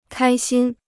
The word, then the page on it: 开心: kāi xīn